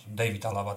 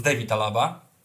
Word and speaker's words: David Alaba: David Alaba, David Alaba teraz przecież. No bo tak, tak, tak mamy wymawiać, mówił. Więc David Alaba.